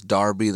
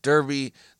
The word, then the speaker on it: Derby: We don't, it's an accent. It's not a different word. It's just in their accent, Derby, they pronounce it as Darby. In our American accent, we say Derby.